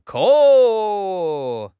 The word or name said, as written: ke4